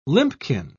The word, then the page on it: limpkin: British English